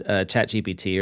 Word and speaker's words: ChatGPT: So fun fact about the word ChatGPT or the term ChatGPT. In French, it means, it's pronounced Chat GPT, which means kitty, I have farted.